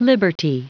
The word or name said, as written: liberty